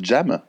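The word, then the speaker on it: Dżem: Alors, ça, ça, ça se prononce Jam, ça s'écrit j'aime, D-Z-E-M.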